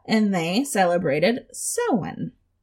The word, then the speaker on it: Samhain: Samhain is how I would pronounce it. But this is in Gaelic. In Gaelic, it's pronounced Sowen.